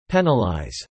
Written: penalize

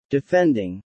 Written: defending

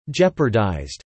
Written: jeopardized

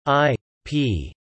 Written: I.P.

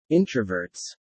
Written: introverts

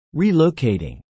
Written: relocating

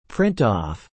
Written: print off

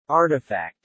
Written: artifact

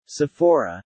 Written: Sephora

